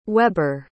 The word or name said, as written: weber